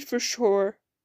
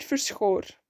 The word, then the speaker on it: Verschoor: P1 to P3 in group A was Victor Martins in 1, Jack Doohan in 2, and Richard Verschoor, I said that very weird. Richard Verschoor, that's how you say it.